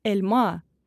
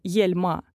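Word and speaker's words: elma: Таким образом, слово «эльма» — «яблоко» будет произноситься «эльма», а не «ельма».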